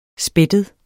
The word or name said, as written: spættet